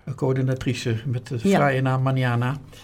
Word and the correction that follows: Manana: Manana. – Oh, Manana moet je daar spreken. Ja, ja, ja. Oh ja, de klemtoon ligt daar.